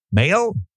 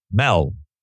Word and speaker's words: Mehl: Now, the author of the article is Darren, I hope I say it right, M-E-H-L. Male? Mel. Sorry.